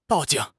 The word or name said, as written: 报警